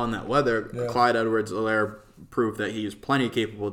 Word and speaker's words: Edwards-Helaire: So I think this is a prime Clyde Edwards-Helaire game. The H is silent.